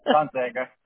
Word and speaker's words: Gonzaga: Did I say it right, Gonzaga? – Gonzaga.